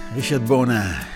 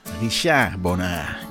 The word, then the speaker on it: Richard Bona: Richard Bona. Richard Bona. Je moet het op zijn Frans uitspreken.